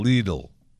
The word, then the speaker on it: Lidl: It says pronounced L-E-E-D-I-L, Lidl. So let's hear it again. – Lidl. – L-I-D-L.